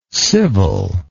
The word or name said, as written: civilness